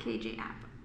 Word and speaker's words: KJ Apa: KJ Apa. – KJ Apa. – Is that how you say his name? – Yeah.